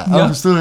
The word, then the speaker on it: Angostura: Angostura. – Zeg ik het goed? Ja, ja, ja. Angostura, ja.